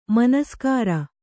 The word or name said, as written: ಮನಸ್ಕಾರ